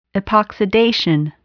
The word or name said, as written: epoxidation